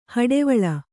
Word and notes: ಹೊಡೆವಲೆ: kannada

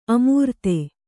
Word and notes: ಅಮರ್ದು: amardu; kannada

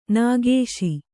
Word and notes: ನಿಕಸ: Kannada

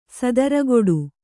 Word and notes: ಸದರಗೊಡು: sadaragoḍu

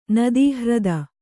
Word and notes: ನದೀಹ್ರದ: Kannada

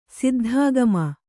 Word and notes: ಸಿದ್ಧಾಗಮ: siddhāgama

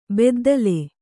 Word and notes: ಬುದ್ದಲಿ: buddali

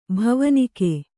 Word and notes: ಭವನಿಕೆ: bhavanike

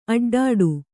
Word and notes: ಅಡ್ಡಡಿ: aḍḍaḍi

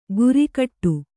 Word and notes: ಗುರಿಕಟ್ಟು: gurikaṭṭu

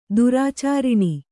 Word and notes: ದುರಾಚರಣೆ: durācaraṇe

